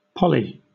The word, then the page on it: poly: British English